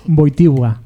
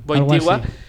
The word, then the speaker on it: Wojtyła: Creo que es Wojtyła. – Wojtyła.